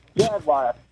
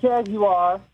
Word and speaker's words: Jaguar: It is pronounced Jaguar, not Jaguar. Jaguar.